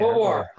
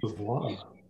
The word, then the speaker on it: Beauvoir: and Jean Bouvier. – Beauvoir. – How do you pronounce the last name? – Beauvoir. – Beauvoir.